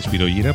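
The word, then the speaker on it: Spyro Gyra: Líder de Spiro Gyra, que se pronuncia así, Spiro Gyra, porque es un nombre latino, el nombre de un protozoo, Seda y Satén, de su álbum Got the Magic, creo que es del 98, si mal no recuerdo.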